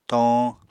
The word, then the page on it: toñ: Apatani